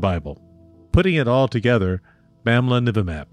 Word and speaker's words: Bamlanivimab: But if you want to pronounce it and sound erudite, start with the last three syllables. Nivimab. Emphasis on the n, kind of like the knights who say n, Nivimab.